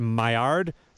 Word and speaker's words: Maillard: I don't know if I'm pronouncing it right, the Maillard reaction.